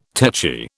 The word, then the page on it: techy: British English